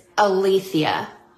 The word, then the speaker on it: aletheia: So the Greek word for truth is aletheia.